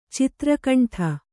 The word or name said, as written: ಚಿತ್ರಕಂಠ